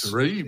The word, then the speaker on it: Atarim: Let's not forget that Vito, English is his second language, so it's Atarim, which is Atarim. It's Hebrew. It's Hebrew for website.